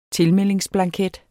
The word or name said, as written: tilmeldingsblanket